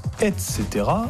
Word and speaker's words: etc.: On prononce etc. et ça s'écrit ETC.